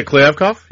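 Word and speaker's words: Kliavkoff: Is it Kliavkov? Is that how you say it? – Yeah, Kliavkov. Yeah.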